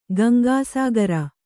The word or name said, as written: ಗಂಗಾಸಾಗರ